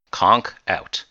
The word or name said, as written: conk out